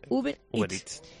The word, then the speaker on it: Uber Eats: Me ha costado aprenderlo, pero lo digo bien. Uber Eats.